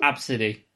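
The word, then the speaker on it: Abcde: Oh, it's supposed to be pronounced Absidy.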